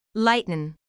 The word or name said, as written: lighten